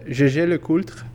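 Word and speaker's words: Jaeger-LeCoultre: All right, we got the Jaeger LeCoultre Joaillerie 101 Manchette.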